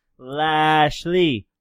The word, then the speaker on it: Lashley: You're saying that wrong? It's Lashley. Lashley.